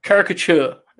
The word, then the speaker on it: caricature: It's close. Caricature. – Okay. – You need to split the C and the T, but it's fine. You're close.